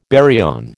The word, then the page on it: baryon: American English